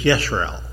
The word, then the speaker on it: Israel: Very similar to the issue of Israel, but this is Yisrael.